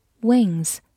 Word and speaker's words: win: 最后提醒大家，有一个词跟它发音很像，w i n win i n 字母组合发的是前鼻音 in win，动词名词表示赢获胜，而我们今天学习的 i n g 结尾是一个后鼻音 wing。 wing，发音和拼写都有微弱的差异，希望大家可以仔细体会一下。